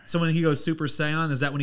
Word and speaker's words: Saiyan: Saiyan.